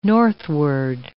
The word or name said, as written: northward